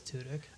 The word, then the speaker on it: Tudyk: Tudyk or Tudyk? – I think it's Tudyk. – Tudyk. – He says Tudyk.